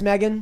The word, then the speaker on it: Meagan: See, I like the M E A G A N because it's like Megan, but it's like they just lie and say it's pronounced Megan.